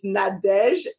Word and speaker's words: Nadege: It's Nadege, N as in Nancy, A as in Apple, D as in David, E as in Edward, G as in George, E as in Edward.